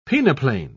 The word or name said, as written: peneplain